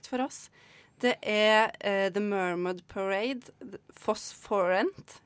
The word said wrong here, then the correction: Phosphorescent: er det riktig uttalt? – jeg vet ikke. det Phosphorescent. Eh, Phosphorescent.